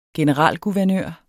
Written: generalguvernør

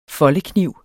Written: foldekniv

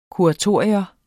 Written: kuratorium

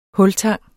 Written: hultang

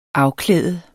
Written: afklæde

